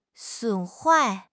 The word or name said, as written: 损坏